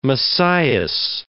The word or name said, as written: Messias